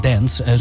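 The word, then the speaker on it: Danse: Maar dit is toch uitgesproken als dance.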